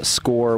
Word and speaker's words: Scorgie: It's Adam Score with a G, like score like a goal, because that's how my name's pronounced.